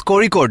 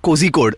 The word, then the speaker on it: Kozhikode: I screwed up on it's Kozhikode, not Kozhikode, as many people think. It's The Z is not pronounced? – No, it's ZH.